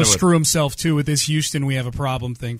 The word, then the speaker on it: Houston: Houston Avenue. Like, yeah. Show off that it's not pronounced Houston. Yeah. It's pronounced Houston up there.